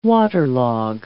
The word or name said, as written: waterlog